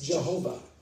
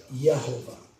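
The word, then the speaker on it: Yehovah (יהוה): The first name is Jehovah. In the Hebrew, it's pronounced Yehovah. Okay, there's no J in the Hebrew language. Even though you see the letter J, it's pronounced Y. So it's Yehovah.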